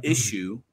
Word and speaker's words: Issuu: Issue, I think it's spelled ISSU. Is that correct? – UU. Yes, two U's. – Two U's. ISSUU. That's where you want to go to find it.